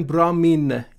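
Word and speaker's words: Brahmin: این کشیش ها تو فارسی شده برهمن، ولی تو انگلیسی میگن برامین، یه آی ان داره، برامین.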